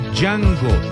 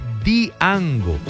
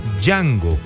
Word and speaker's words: Django: Hace días un señor, que yo no me atrevo a decirle terco, perdón, pero ni siquiera obstinado, pero insistía en que se pronunciaba correctamente Diango, y la D, aunque se escribe el originario del nombre de Django, hace parte del idioma romaní, aunque la D se escribe, no se pronuncia,